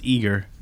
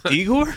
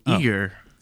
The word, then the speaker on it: Iger: So Bob Iger had his annual... – pronounced Iger. Igor? – No, Iger. – Eeyore. No, Iger.